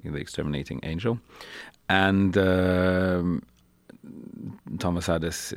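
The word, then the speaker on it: Adès: And I've seen you premiere a number of them, and tonight we'll see here the Gilmore premiere of a new collaboration with Thomas Addis. Is that how you say his name? – Yes,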